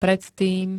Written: predtým

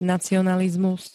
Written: nacionalizmus

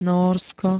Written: Nórsko

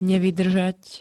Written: nevydrzat

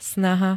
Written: snaha

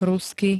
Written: rusky

